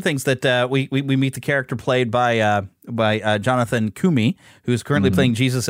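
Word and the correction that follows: Roumie: in The Chosen, but he's plays Rumi. – Kumi. Did I say it right? – I thought it was Rumi. – Let me look him up again here. It's on my page. Yeah, it is Rumi.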